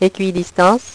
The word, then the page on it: equidistance: French